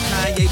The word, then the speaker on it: Kanye: It's Kanye, but some of my plastics still say Kane.